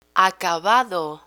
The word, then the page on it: acabado: Spanish